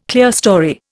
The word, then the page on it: clerestory: Indian